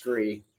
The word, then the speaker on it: SKRE: It is SKRE. It's pronounced SKRE.